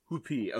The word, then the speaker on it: Whuppie: Whoopie is spelled W-H-U-P-P-I-E. – W-H-U-P-P-I-E. Whoopie. Okay, interesting.